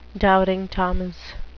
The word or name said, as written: doubting thomas